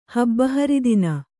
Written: ಹಬ್ಬಹರಿದಿನ

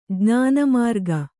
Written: ಜ್ಞಾನಮಾರ್ಗ

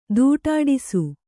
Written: ತಾಟಾಡಿಸು

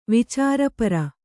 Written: ವಿಚಾರಪರ

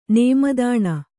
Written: ನೇಮದಾಣ

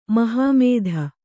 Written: ಮಹಾಮೇದೆ

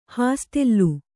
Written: ಹಾಸ್ಟೆಲು

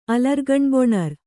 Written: ಅಲರ್ಗಣ್ಬೊಣರ್

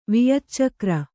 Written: ವಿಯಚ್ಚಕ್ರ

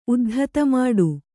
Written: ಉದ್ಧತಮಾಡು

